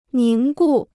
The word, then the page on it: 凝固: níng gù